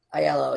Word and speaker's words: Iello: ILO or ELO? – Well, it's spelled Iello. – Yeah, that's right. ILO. It's actually ILO.